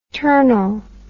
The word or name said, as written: ternal